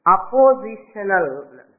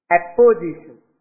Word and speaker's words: apposition: Number two, in English we can call it as samanadhikaranyam, oppositional. Opposition. Not OPP, but APP. Apposition. Not opposition, but apposition.